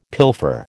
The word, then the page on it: pilfer: American English